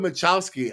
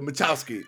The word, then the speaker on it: Michalski: We have Jacob Michalski.